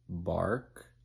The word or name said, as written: bark